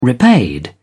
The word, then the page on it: repaid: British English